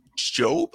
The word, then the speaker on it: Job: So this name, J-O-B, how do you pronounce it? – That would be Job. – There you go. It is Job.